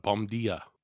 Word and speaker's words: bom dia: Om dia? – Yes, that's bom dia. Bom dia, actually, is how they pronounce it.